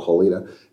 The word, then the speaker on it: Helena: It was called Helena, spelled like Helena, Montana, but called Helena at the time.